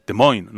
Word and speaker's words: Des Moines: Primero, nos dice Carlos Burgos, nuestro especialista en especiales, que se pronuncia Des Moines, ¿no? – No lo he dicho ni bien.